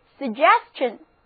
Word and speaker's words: suggestion: 同样的，在 suggest 后面加上 ion 的名词后缀，就是 suggestion. S-U-G-G-E-S-T-I-O-N suggestion 字母组合 T-I-O-N 读作 chen，单词就读作 suggestion。